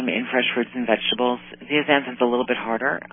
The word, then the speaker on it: zeaxanthin: It's lutein and zeaxanthin.